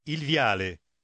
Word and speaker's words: viale: Слушайте и повторяйте, будучи уверенными, что вы повторяете итальянское произношение. – Il viale.